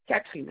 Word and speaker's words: Catherine: Madame Kinsey Catherine. – Ça s'écrit comment, s'il vous plaît? – Q-U-I-S-T-I-N. – Très bien. Catherine, merci. – Vous m'avez dit. – Catherine, avec un C-A-T-H.